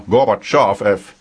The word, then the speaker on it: Gorbachev: And now comes Mr. Gorbachev, or Mr. Gorbachev, as he is pronounced in this country, I believe.